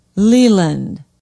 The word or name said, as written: Leland